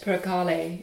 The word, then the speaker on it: percale: Percale. – Yes, yes. – Is that how you pronounce it? – Percale. Yes. I do.